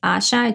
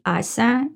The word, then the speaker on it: Asia: And even though everybody says Asha, it's actually Asa. It's pronounced differently. It doesn't really matter. But as you spell it, it's A-S-I-A, you know, like Asia.